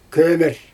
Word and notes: kömer: Xyzyl

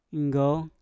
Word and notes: nggouw: Mpur

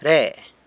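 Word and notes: re: San Lucas Quiaviní Zapotec

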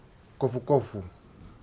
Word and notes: kofukofu: futuna aniwa

